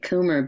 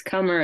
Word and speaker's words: Comer: Comer, C-O-M-E-R. – I was just pronouncing it Coomer, but if it's Comer, it's Comer.